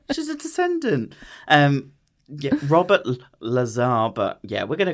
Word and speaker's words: Lazar: Okay, so it is pronounced Lazar, but it's spelt laser.